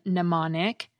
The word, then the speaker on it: mnemonic: T-E-R. The same thing happens with the Greek root M-N-E-M, meaning mind. The M is silent in mnemonic, but in the word amnesia, it gets pronounced as the end of the syllable M.